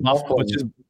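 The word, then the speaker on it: Byfuglien: I mean, I think the name that comes to my mind is Dustin Byfuglian, which is pronounced Bufflin, which is pronounced Bufflin, which nobody in their right mind that's ever never heard of that guy that's seen that name would say Bufflin.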